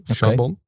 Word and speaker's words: Charbon: Eh, volgens mij spreek je het uit als Garbon. Oké, Charbon.